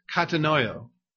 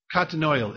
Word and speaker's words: katanoeo: The word consider here is translated from the Greek word katanoio. Katanoio. It's spelled K-A-T-A-N-O-E-O.